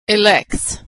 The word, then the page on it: elects: s